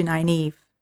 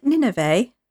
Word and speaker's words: Nynaeve: You remember that when Leandrin's trying to cozy up to Nynaeve, she starts off by saying, she mispronounces her name and she says, "Is it Nineveh?" – Thinking of Nineveh and Jonah.